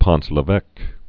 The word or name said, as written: Pont l'Eveque